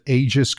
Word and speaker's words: Aegis: Is this how you pronounce it? Aegis cruisers? – Yeah, that sounds about right.